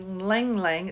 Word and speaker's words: ylang: The buds on the Lang Lang, it's spelled Y-L-A-N-G, Lang Lang tree.